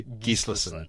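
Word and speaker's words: Gíslason: Normally with Icelandic, the correct way is to hit the first syllable. It took me a long while to realise that too. So the real way, I was getting this wrong, is Kari Gislason.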